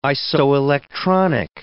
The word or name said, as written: isoelectronic